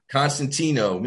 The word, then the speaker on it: Constantino: Constantino.